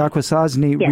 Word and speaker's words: Akwesasne: The Akwesasne refusing to participate in the census, and their refusal is a political refusal.